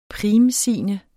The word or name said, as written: primsigne